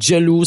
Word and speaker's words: Jel2o: Le duo, c'est Jello. Ça s'écrit J-E-L-2-O.